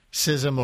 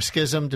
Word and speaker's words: schism: Do you feel like you brought it on in some way, or that this schism or schism, depending on your pronunciation preference, was inevitable?